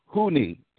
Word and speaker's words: Huni: Okay, so phonetically, the best way to pronounce my name is like saying who, uh, like you're asking who and me together. Okay, so it's Huni. That's my first name, and the second name is Bak, B A K sound almost like a dog bark without the R, but it's bark.